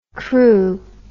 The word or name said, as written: Kru